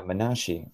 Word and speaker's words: Menashe: I had actually never heard of Menashe. Is that right? Did I pronounce that correctly? – Yeah, Menashe.